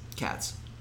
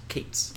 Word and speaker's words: Katz: E.L. Katz? Cates? – Katz. – Well, I work for a company and it's pronounced Cates. – Oh, and it's spelled that way. – Your company's pronouncing it wrong.